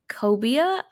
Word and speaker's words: cobia: With some items like sweet corn bisque, strawberry salad, butter poached Florida cobia. Am I saying that right? – Yes, excellent.